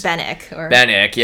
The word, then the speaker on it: Benwick: I guess it's Benwick. – Yeah, I guess you don't pronounce the W.